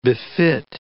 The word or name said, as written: befit